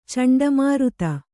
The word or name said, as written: ಚಂಡಮಾರುತ